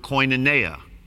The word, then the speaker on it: koinonia: depending on how you pronounce it. For Abe out there, he, will pronounce it koinonia, most likely. Most of us will pronounce it koinonia, but he's, the, he's correct because he's Greek.